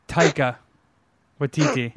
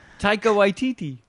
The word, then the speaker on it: Taika Waititi: Taika Waititi. All right, yeah, you got me. I zoned out a little bit. Say it again. – Taika Waititi.